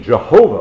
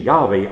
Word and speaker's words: Yahweh: And then we think of those names of God, the very name Jehovah, or another pronunciation, Yahweh, I am who I am, and the compounds of that name, Jehovah Jireh, the Lord will provide.